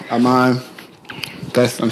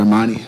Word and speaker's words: Iman: All right, so Iman. I'm saying that right. – Mm-hmm. – Okay, just gotta make sure. Like when I first hit her up to get on the podcast, I was calling her Imani,